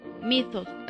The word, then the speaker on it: Mythos: Mythos. así se pronuncia. – Creo que sí. – M-Y-T-H-O-S.